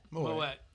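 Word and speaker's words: Moët: Moet. – How do you say that? – Moet. That's it.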